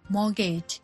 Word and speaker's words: mortgage: mortgage แต่เราไม่ออกเสียงตัวทีนะคะอ่านว่า mortgage